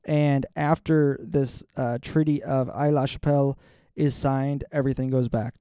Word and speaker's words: Aix-la-Chapelle: The Treaty of Aix-la-Chapelle. I'm pretty sure the X is silent. Aix-la-Chapelle, 1748.